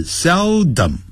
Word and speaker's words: seldom: He seldom，好，这个字要念成 seldom， sel，再来 dom。